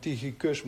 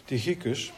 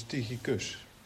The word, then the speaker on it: Tychicus: In de Griekse grondtekst zeg je Tychikos. Vandaar dat het accent ligt op de laatste lettergreep. Dus we zouden eigenlijk Tychicus moeten zeggen. Ik zei altijd Tychicus, maar het is Tychicus.